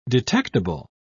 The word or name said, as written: detectable